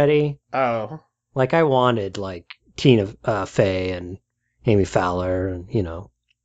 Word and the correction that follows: Poehler: You mean Amy Poehler? – Poehler, that's how you say it.